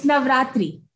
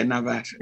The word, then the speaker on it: Navaratri: How do you say it? – It's Navaratri. – Okay, Navaratri. Okay.